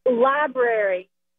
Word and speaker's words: library: Library. – Library. – It's library. – It's a library. – Even adults say it, and I'm like, that's not how you say it. – There's an R in there.